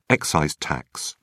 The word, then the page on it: excise tax: British English